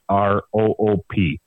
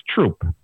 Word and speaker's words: Throop: It's spelled THROOP. So to a newbie, that's throop. But to here, it's troop.